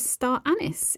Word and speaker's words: star anise: which I learnt in the reading of the Calm Christmas audiobook for the States, is pronounced as star anise in America. That was a new fact for me.